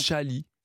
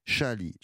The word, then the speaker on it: CH2ALIS: Ça s'écrit C-H-2-A-L-I-S et ça se prononce Chali. – Chali.